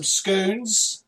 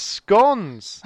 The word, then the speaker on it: scone: No, it's scones. – Is it? – You're saying it wrong because because there's a very simple way to work it out. You say, now it's here, now it's scone.